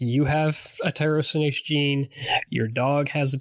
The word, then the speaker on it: tyrosinase: The gene is called tyrosinase.